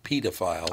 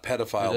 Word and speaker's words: pedophile: It's pedophile, actually, as in pediatrician, pedophile. – Oh, is it really? – Not pedophile, like in pedestrian.